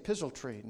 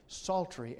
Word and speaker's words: psaltery: psaltery. No, that is not psaltery. That P is silent. It's psaltery and harp.